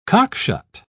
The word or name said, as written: cockshut